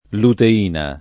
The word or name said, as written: luteina